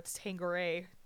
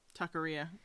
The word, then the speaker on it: Taqueria: I had tacos from Lone Star Tangare, or is it Taqueria? – Taqueria.